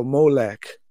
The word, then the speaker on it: Molech: And so what they did was they took the Ammonite word Melech and inserted the O there, playfully using the same O pronunciation as their word Boseth, which means shame. And so they were saying, you don't have a Melech, you have a Molech.